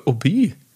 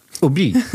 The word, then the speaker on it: Obi: Das ist keine Abkürzung oder so, sondern das ist die Lautschrift der französischen Aussprache des Wortes Hobby. – Obi? – Obi.